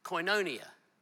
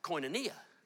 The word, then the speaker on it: koinonia: Fellowship, the Greek word is koinonia. Or if you put the emphasis on a different syllable, koinonia.